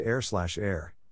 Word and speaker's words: H31R: H31R, pronounced air/air, is an experimental electronic hip-hop duo composed of New Jersey producer/composer Jay Words and Brooklyn rapper/vocalist Masai.